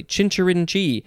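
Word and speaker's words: chincherinchee: I think it's pronounced chinchirinchi. Chinchirinchi. You got to emphasize the last syllable mostly. Chinchirinchi. C-H-I-N-C-H-E-R-I-N-C-H-E.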